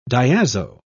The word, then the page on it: diazo: British English